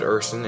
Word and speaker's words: Ursin: And of course, my name is David Urson, and my last name is spelled U-R-S-I-N. And so I thought, well, I'm going to be traveling all over the world. How about David Urson International? – Yeah.